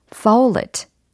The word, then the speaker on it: folate: 그래서 foul it, foul it, foul it. 예, 이렇게 발음해 주시면 되겠어요. They are rich in folate.